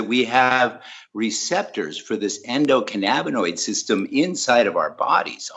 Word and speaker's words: endocannabinoid: Yeah, the endocannabinoid system or endocannabinoid. Yeah. It just depends on how you want that A to sound.